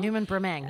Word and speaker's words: Newman-Bremang: Yeah, Newman Brimeg.